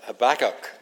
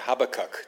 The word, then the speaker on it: Habakkuk: of Habakkuk, or Habakkuk, depending on which way you pronounce it. Different people pronounce it differently Habakkuk or Habakkuk.